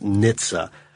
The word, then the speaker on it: NHTSA: NHTSA is not pronounced, and he spells it out. – NHTSA. – Yeah. It's like, by those of us that worked with them, it's pronounced NHTSA.